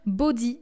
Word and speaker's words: Bode: Donc, elle est née pas longtemps après son grand frère Tyler et plusieurs années avant son petit frère Bodie. Donc, ça s'écrit Bod, B-O-D-E, mais ça se dit Bodie.